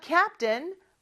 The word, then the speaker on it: captain: 자, 뜬이라고 발음하지만 T-A-I-N, 요 스펠링 잘 기억해 주세요. 자, 그러면 얘기해 볼까요? He, 여기서 he는 손흥민이겠죠? He is the captain of the Korean team.